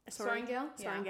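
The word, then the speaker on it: Sorrengail: Also, not only that, the history that her name holds, being a Sorengale... Did I say that right? – Sorengale, yeah. – Okay.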